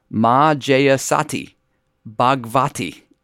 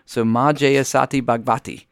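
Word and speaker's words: Ma Jaya Sati Bhagavati: Her new name became Ma Jayasati Bhagavati. So Ma Jayasati Bhagavati. I think I'm saying that correctly. – Yeah, you definitely are.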